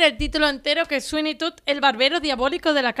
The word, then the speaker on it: Sweeney Todd: Yo elegí Sweeney Todd.